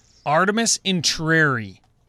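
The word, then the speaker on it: Artemis Entreri: Artemis Intrieri.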